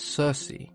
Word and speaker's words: Circe: when they were Latinized and Anglicized, the hard K sound became a soft C, and we now refer to her as Circe.